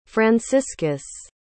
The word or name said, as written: franciscus